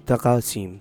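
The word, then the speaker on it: Taqasim: Adesso ci ascoltiamo Takasim, è la pronuncia esatta? – Takasim, sì.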